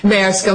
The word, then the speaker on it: Maersk: Mask, that case discusses the Siemens case. That case does discuss... – Did you mean Maersk? – Maersk, I'm sorry, Your Honor, yes. I pronounced it incorrectly.